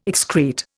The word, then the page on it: excrete: Indian